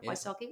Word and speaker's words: Wysocki: And this woman was called Sheila, and I think you would pronounce her surname Wysocki, which I presume is like a Polish name. W-Y-S-O-C-K-I. Wysocki? The Y would be an I. Wysocki?